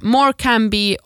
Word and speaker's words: Morecambe: Och Morecambe säger man tydligen Morecam, alltså M-O-O-R-K-A-M, Morecambe. Och sen Gloucestershire så är Gloucestershire.